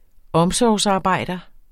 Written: omsorgsarbejder

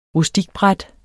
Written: rustikbræt

